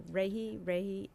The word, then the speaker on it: Rahe: And so they devised this rating scale called the Holmes, tell me if I'm pronouncing this correctly, Rahe, R-A-H-E scale.